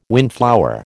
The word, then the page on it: windflower: American English